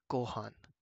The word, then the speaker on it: gohan: And that is rice, or gohan. To spell that, it is GOHAN.